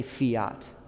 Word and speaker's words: fiat: It doesn't spell the way it sounds, necessarily. Close, There's an F at the beginning, there's a T at the end. You're very close. Yeah. Fiat. Yeah. – F-I-A-T. – Yeah, there you go. Fiat. Perfect. Well done. F-I-A-T.